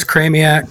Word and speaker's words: Chramiec: Chramiec. – Chramiec. – Similar to Maniac.